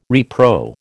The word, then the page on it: repro: American English